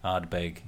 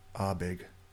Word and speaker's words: Ardbeg: So you do pronounce the D and the B. Ardbeg.